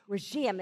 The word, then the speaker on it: Rhegium: From there, after making a circuit along the coast, we reached Rhegium. It's the accent on the second syllable.